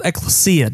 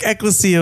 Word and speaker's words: Ecclesia: A lot of people pronounce it wrong. Mm-hmm. They say Ecclesia, but it's Ecclesia. Ecclesia.